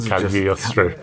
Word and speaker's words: Cagliostro: Cagliostro. – I don't think you pronounce the G. I don't think you meant to, because I'm assuming it's Italian, so it'd be Cagliostro.